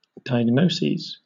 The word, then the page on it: diagnoses: British English